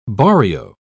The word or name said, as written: barrio